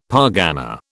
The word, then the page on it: pargana: British English